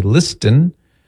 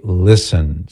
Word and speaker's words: listen: It just doesn't sound right if you pronounce the T. We don't say listen. – No. – We say listen.